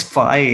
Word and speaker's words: FI: It's FI, as in, like, you know, an I or high fives, right?